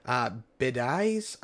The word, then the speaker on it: Biadasz: So you're relying on a fourth round draft pick from 2 years ago in Tyler Bidaiz, I think is how you pronounce his last name. B-I-A-D-A-S-Z. – Yeah, that's close enough.